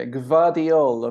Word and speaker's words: Gvardiol: Um, I'm going to go for uh, Grat Grat Diol. Uh, can you help me with the pronunciation? – Yeah, Gvardiol of Croatia.